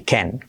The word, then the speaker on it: can: Por lo tanto, tenemos must, tenemos should, tenemos might, tenemos can, que por cierto se pronuncia como el novio de Barbie, can, ¿vale?